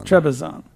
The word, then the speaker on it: Trebizond: How do you say it? – Trebizond.